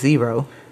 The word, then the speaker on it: Xero: A couple of tools that you can use are, one, what I use is Xero. It's spelled X-E-R-O, so not zero like the number, but Xero.